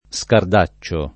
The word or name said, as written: scardaccione